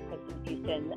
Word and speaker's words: Kristyn Fuston: Uh, and I do have a LinkedIn page under Kristyn Fuston. Uh, the last name is spelled uh, F U S T O N. First name is K R I S T Y N.